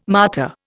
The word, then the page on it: martyr: Indian